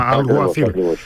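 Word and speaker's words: Alguacil: Το γάμα κάπα να βγει και να βγει το κάπα και να μείνει μόνο το γάμα. Αλγουαθίλ.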